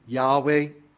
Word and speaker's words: Yahweh: In the hymn that we sang it Jehovah. Students these days usually pronounce it Yahweh.